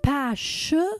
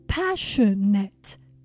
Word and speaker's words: passionate: Please be careful with the pronunciation of the adjective passionate. It's not passionate, it's passionate. Passionate.